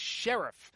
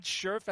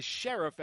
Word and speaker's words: Scherf: It is Scherf. It's not Scherf as it's spelled. It's not Shreve or whatever. It is Scherf as in a law enforcement officer with a star on his chest.